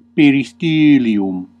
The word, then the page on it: peristȳlium: Classical; /pe.riˈstyː.li.um/